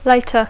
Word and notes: later: English

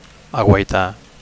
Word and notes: aguaitar: Catalan

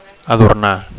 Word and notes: adornar: Catalan

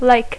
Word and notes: lake: English

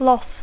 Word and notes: loss: English

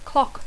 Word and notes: clock: English

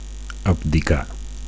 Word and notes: abdicar: Catalan

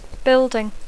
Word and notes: building: English

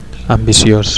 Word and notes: ambicios: Catalan

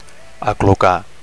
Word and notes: aclucar: Catalan

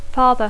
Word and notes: father: English

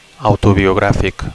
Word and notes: autobiografic: Catalan

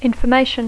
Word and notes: information: English

